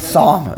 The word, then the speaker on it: psalm: Psalm. You don't pronounce. Psalm. – I know. Like psalm. – It's like pneumonia.